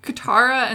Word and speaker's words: Katara: But recently, the two main characters, Katara and Sokka, am I saying this correctly? – Yeah.